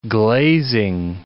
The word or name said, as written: glazing